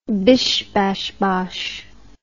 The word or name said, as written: bish bash bosh